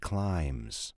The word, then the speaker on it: climb: A bean is the seed of a plant that climbs. A bean is the seed of a plant that climbs. – How do you spell climb? – C-L-I-M-B. Remember, the B is silent.